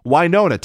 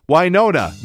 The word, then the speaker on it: Wynonna: Wynonna, take two. Wynonna, no one else on earth on 105.5.